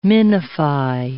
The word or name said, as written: minify